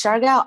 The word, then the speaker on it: Siargao: how do you say it? Siargao? – Yes, it's Siargao Island.